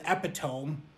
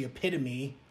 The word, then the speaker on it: epitome: Started with epitome, which is actually epitome, I've now learned, but I've been saying epitome for the better half of my life.